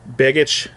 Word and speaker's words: Begich: And Representative Nick Begich, I believe is how you pronounce it. – Begich.